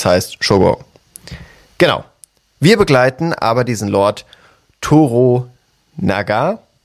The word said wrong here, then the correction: Toranaga: Toranaga, Toranaga, genau. So ist es ausgesprochen, richtig.